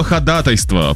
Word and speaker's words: ходатайство: Слово «ходатайство» правильно произносят в основном только юристы.